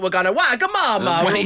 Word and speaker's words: Wagamama: No, it's spelt Wagamama, and that's how it's pronounced.